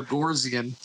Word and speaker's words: Bogosian: I always add an R to his name, a forced R. – Bogosian.